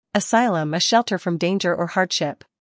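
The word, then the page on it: asylum: uk əˈsaɪləm; en-us əˈsaɪləm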